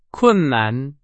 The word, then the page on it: 困难: Chinese